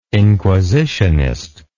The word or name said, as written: inquisitionist